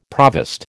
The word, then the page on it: provost: American English